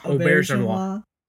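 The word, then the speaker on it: Auberjonois: Auberginois. – So I think you're supposed to think like the French pronunciation of the eggplant, okay. Aubergine, auberginois.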